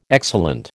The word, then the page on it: excellent: American English